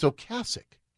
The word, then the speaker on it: Ocasek: And I always thought his name was Rick Ocasek. It's Ocasek. – No way. Really? – 35 years I've been aware of this band, and they even made a point that everybody says his name wrong.